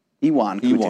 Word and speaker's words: Ion Cutelaba: Ion Cutelaba.